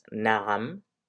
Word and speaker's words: na'am: The first is the correct way to say it, and that's na'am, na'am, na'am, na'am.